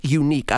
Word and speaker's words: Yuneec: The company is called Yuneec. I love the way they spell it. Y-U-N-E-E-C.